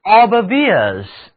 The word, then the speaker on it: obvious: 我们来给词根路、道路 VI 加上用于强调的前缀 OB, 后面再添一个形容词后缀 ous，得到的是 obvious， obvious。这里的字母 o 读音是圆口型的元音 o，那么三个字母 i o u 合在一起读音是 ear， ear。来跟我读 obvious， obvious。